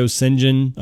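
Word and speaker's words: Şengün: Alperen Sengun, or I say Sengun. I think that's how you pronounce it. I've seen Sengun, and I'm like, that is not how you pronounce it. So I'm going to go Sengun and pronounce it that way.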